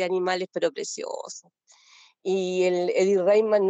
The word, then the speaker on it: Eddie Redmayne: Eddie Redmayne, ¿se llama? ¿Cómo se pronuncia? – Sí, sí, Eddie Redmayne.